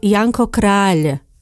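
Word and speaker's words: Janko Kráľ: Try to say Janko Král. Many Slovaks pronounce the surname of the romantic poet incorrectly. Král is not the same as Král, which means king in Slovak.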